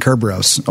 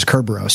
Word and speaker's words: Kerberos: named Kerberos, or I think that's the Greek pronunciation. Latin is Cerberus.